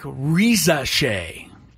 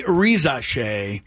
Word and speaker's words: Risacher: But it's looking like Rizache. Re, which is the R-I part. Za, S-A. And then C-H-E-R is pronounced Shay.